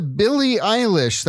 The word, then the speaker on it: Billie Eilish: There's a Billie Eilish.